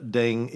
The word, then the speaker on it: Deng: By the way, if it's Deng or it's Deng, depends. In the UK, English, it's Deng. In America, I think you pronounce it Deng. – Mm-hmm. – Either is acceptable.